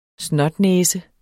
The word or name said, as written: snotnæse